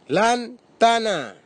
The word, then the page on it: lantana: Bislama